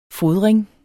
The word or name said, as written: fodring